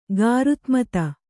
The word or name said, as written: ಗಾರುತ್ಮತ